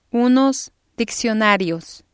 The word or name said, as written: unos diccionarios